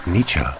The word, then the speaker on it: Nietzsche: Also, a unique and strange number from Sydney, Australia's Stalking Like Candy on Friedrich Nietzsche.